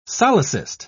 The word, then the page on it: solecist: British English